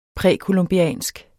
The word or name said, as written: præcolumbiansk